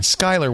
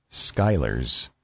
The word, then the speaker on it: Schuyler: And Skyler, one more time, S-C, if you're not Dutch, you probably don't know how to pronounce it. S-C-H-U-Y-L-E-R, Skyler's Monster Blog.